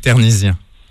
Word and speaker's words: Ternisien: Loïc Ternisien. – Comment ça s'écrit le... – T-E-R-N-I-S-I-E-N. – N-I-S, vous avez dit ? – I-E-N.